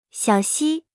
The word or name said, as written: 小溪